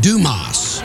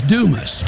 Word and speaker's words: Dumas: He talked about Dumas. – Did I mispronounce it? – Yeah, it's Dumas, but that's okay.